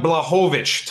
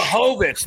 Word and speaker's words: Blachowicz: Jan Black, pronounce these two for me, or pronounce that one for me. – We got Justin, you got Gaethje, but Jan Blachowicz, tough one there, Polish. – Blachowicz, that's what it was.